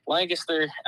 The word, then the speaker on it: Lancaster: Lancaster. I tell everybody it's, you just pronounce it one syllable. You just, all one word, just run it together.